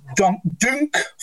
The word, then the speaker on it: Dunk: When we, for example, did a cover, like a landing page for Dunk Festival, as I now know it's pronounced.